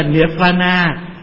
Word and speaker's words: Nirvana: Niết Bàn là tiếng Phạn, mình đọc là Niết Bàn là Nirvana.